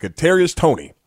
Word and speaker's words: Kadarius Toney: And a guy, please remember, how do you say this first? Is it Kadarius Toney? – Yes.